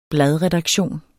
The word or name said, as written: bladredaktion